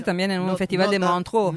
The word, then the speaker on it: Montreux: Montreux, no lo sé pronunciar bien. Montreux. Montreux, ahí está. Sí, es en Suiza, pero es una palabra francesa.